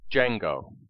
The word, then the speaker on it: Django: In the name Django, the DJ is pronounced with a J sound. "What's your name?" asks the stranger in the white hat and purple ascot. "Django.